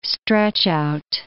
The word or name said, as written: stretch-out